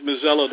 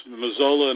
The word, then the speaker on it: Mazzella: With a name like Mazzella, that always gets Mazzola and everything else, I try to.